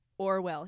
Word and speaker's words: Orual: And to make it very clear, we pronounce it Orwell here. – Yeah. There is no consensus on the main character's name. – To be clear, it is spelled O-R-U-A-L.